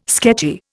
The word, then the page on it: sketchy: Indian